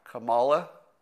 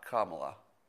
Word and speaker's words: Kamala: And so it's Kamala, not Kamala. I believe that is correct.